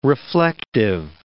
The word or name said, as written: reflective